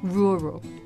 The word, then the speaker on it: rural: 好的，第一个单词他觉得比较难的就是这个 rural. Can you spell it? – Yeah, of course I can. It's R-U-R-A-L, but it makes a sort of R-O-O sound, like rural. – Okay. 这个单词的拼写是 R-U-R-A-L.